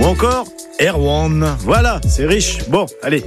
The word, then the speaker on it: Erwan: Mais la seule chose est sûre, Saint Erwan se prononce comme ça quand on parle de lui. Hein, car il était originaire du Trégor, et là-bas on disait Erwan.